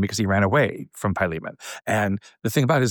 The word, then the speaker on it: Philemon: It may be written Philemon, but the way you pronounce it is Philemon.